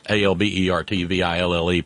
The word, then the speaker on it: Albertville: That's Albertville, pronounced Albertville.